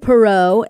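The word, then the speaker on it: Poirot: Yeah, so you have Detective Perot and Book. – Yes. – That's how they pronounce his name.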